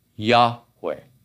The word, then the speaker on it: Yahweh: Most scholars today believe that that should be pronounced Yahweh.